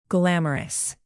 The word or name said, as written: glamorous